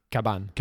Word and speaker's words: Cavan: Cabane. Cabane. Cabane qui est. – Les v, cabane? Les, ça s'écrit avec un V, mais les V en espagnol se prononcent un peu comme des B.